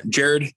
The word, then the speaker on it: Jared: Jared, J-A-R-E-D, because I know there's one way to spell it right, 10,000 ways to spell it wrong. Renyer, R-E-N-Y-E-R.